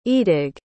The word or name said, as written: eadig